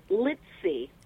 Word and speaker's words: Litze: Yes, Litze. – Oh, it's Litze. I wasn't sure if it was Litz or Litze.